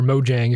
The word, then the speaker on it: Mojang: He founded this company called Mojang, or Mojang, if you want to use the American pronunciation of it.